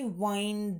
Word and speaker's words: wind: Практически одинаково пишем, но именно глаголы, они у нас через I произносятся. Wind. I wind down.